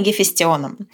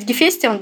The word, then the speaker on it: Гефестион: Я буду э, ударение ставить Гефестион, да, можно говорить Гефестион.